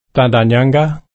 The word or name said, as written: tarndanyangga